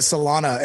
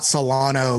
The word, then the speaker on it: Solana: Thank you for calling it Solana and not Solano, like so many mispronounced.